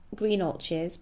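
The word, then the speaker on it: Greenhalgh's: Even in the expensive pie shop, which is Greenalches, but it's not spelt Greenalches, it's spelt Greenhalls, but everyone calls it Greenalches.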